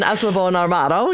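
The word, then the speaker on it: Eslabón Armado: No, you did a great job on Eslabon Armado.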